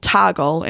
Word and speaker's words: Toggl: I use a timer app called Toggle, and it's spelled T-O-G-G-L, and it runs on the computer.